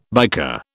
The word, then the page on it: biker: British English